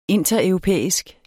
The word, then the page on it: intereuropæisk: [ ˈentʌ- ]; [ ˈenˀtʌ- ]